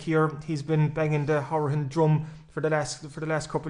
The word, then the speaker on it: Hourihane: But I think it's safe to say Hourihane is the pronunciation that we want down in West Cork.